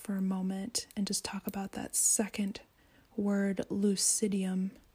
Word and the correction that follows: lucidum: or lucidum, actually, is probably how it's pronounced.